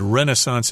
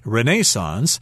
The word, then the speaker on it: Renaissance: I should mention that Renaissance is the American pronunciation. I think in England they say Renaissance, but we're talking about a period of history referred to as the Renaissance of the 1500s.